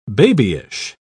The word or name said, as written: babyish